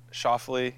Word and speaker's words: Schauffele: I've sort of ruined it for everyone. I've managed to give two names or two pronunciations of my last name without really realizing it. It's Schoffle is the easy version when I see a starter panicking.